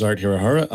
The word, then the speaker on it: Art Hirohara: My name is Art Hirohara.